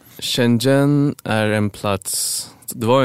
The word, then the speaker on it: Shenzhen: Ni etablerar huvudkontor i Shenzhen i Kina. Mm-hmm. Uttalar det rätt eller hur? – Ja, Shenzhen. – Shenzhen, okej.